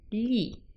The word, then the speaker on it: gli: So to say for him, we say gli, spelt G-L-I. And it's pronounced, it's a sound that doesn't exist in English. It's pronounced by making a big smile and squashing the top of your tongue flat against the roof of your mouth.